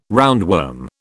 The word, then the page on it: roundworm: British English